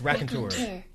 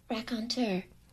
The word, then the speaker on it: raconteur: It's raconteur. – Okay. – Raconteur.